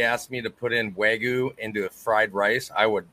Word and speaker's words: Wagyu: Wagyu. – Wagyu.